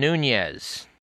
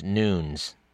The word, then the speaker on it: Nunes: You've heard him in the media described as Devin Nunes, which is how you would pronounce his name if he were of Spanish ancestry. Unfortunately, he is, like myself, of Portuguese ancestry, and the correct pronunciation of his name is Nunes.